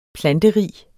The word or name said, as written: planterig